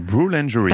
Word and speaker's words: Brewlangerie: Alors leur, et c'est là que vous allez me reprendre pour la prononciation, leur boulangerie. – La brewlangerie. – Parce que le mot brew en anglais veut dire brasser, va demander une certification bio et on leur souhaite de l'obtenir.